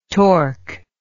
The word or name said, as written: torc